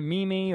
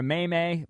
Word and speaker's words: meme: But they're goofing on Jim Nantz because he mispronounced meme, apparently, on the broadcast of the NCAA tournament. Did he pull a Bryce? Did he call it a meme or a may-may? Bryce called it a may-may, right? – Yes.